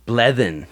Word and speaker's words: Blethen: Bleden. – How do you spell that? – B-L-E-T-H-E-N.